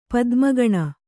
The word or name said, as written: ಪದ್ಮಗಣ